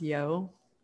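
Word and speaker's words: Yeoh: And it has Michelle, I think her last name is pronounced Yeo. – Yeah.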